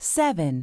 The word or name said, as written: 7